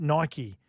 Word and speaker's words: Nike: Brad from Redhead says, "Hi, for your information, Nike is pronounced Nike." – I said that. – It's Latin for victory, or in Greek mythology, goddess of victory.